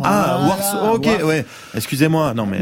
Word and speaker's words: Warsaw: Warsaw. non, non, mais ça se prononce comme ça.